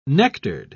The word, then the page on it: nectared: British English